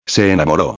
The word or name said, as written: se enamoro